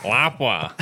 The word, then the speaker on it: Lapua: And granted, these can change, but don't you mean Lapua? – Lapua. – Lapua. – Lapua. – That's how Greg's father pronounced it. Lapua.